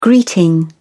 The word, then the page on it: greeting: British English